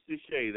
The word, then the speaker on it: DeShay: James Deshay. That last name is spelled D-E, capital S-H-A-Y.